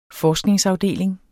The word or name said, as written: forskningsafdeling